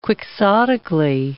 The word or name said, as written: quixotically